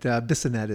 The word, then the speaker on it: Bissonette: Bissonetti would have been, I was like, hey, come on. But Bissonette is pretty Bissonette.